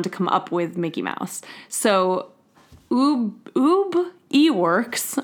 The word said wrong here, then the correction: Iwerks: I don't know how to say this, Iwerks, okay.